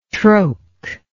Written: troke

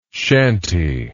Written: chanty

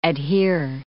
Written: adhere

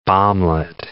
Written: bomblet

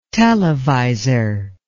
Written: televisor

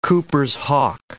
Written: Cooper's hawk

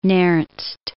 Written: Nernst

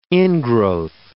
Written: ingrowth

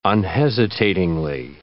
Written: unhesitatingly

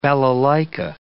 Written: balalaika

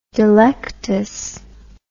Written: delectus

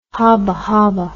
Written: habahaba

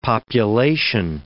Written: population

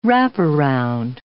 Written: wraparound

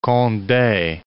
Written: Conde

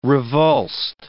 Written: revulsed

